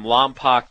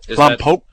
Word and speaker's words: Lompoc: I read that your dad is from Lompoc, California. – Lompoc? – Is that true? I'm actually asking this to see if will pronounce Lompoc correctly. – Yeah, you missed. You messed it up.